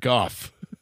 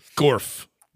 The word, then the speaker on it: Goff: Used to call Jared Gorf. – Gorf. – That's true. You did. – He's amazing. – Very rude to mispronounce anyone's name.